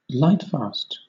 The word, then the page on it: lightfast: British English